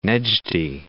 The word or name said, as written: Nejdi